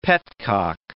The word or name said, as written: petcock